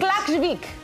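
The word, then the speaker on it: Klaksvík: Klaksvik, Klaksvik, and Lille.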